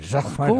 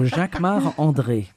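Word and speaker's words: Jacquemart-André: You say it like André. A Frenchman. – Jacques-Marc-André. Of course, because I'm French. So Jacques-Marc-André.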